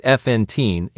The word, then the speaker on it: Fontein: Common spelling variants are Fontein, Fontein, Fontein, and Fontein, each pronounced Fontein in Dutch.